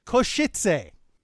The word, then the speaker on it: Košice: national teams in, James, I learned the correct pronunciation watching the streams. It is Košice.